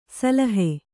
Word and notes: ಸಿಲ್ಹ: Kannada

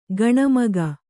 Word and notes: ಗುಣಮುಖ: Kannada

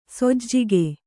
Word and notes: ಸೋಜಿಗೆ: sōjige

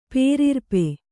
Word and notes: ಪೇರಿರ್ಪೆ: pērirpe

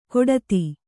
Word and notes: ಗೊಡಧಿ: Kannada

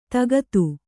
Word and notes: ದಗದ: Kannada

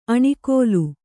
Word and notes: ಆಣಿಗಲ್ಲು: āṇigallu

